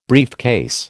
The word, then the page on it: briefcase: American English